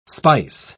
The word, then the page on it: speiss: British English